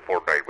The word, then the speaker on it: Fortnite: eh, Fortnite, ¿no? Fortnite, eh, no sé, ¿cómo se escribe? se, dice Fortnite, pero se escribe Fortnite.